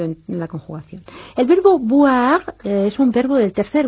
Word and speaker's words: boire: Atención a la diferencia de pronunciación boire, bilabial, B, con voir, que es el verbo ver. Boire, beber, voir, ver. Haga bien esa distinción, porque marca una distinción de significado.